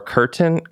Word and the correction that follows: Kirtan: Kirtan, I think it's pronounced. – Yeah, yeah, Kirtan.